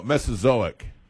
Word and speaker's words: Mesozoic: Do you know how to pronounce it? – No, I don't even know how to say it. I don't even know how to say it. What is it? What is that? – Mesozoic. – Yeah, that's it.